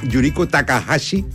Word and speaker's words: Yuriko Takahashi: Pero bueno, sigamos conociendo del mundo del arte, del mundo de las letras, porque nos acompaña Yuriko Takahashi, ¿lo pronuncié bien? – Perfecto.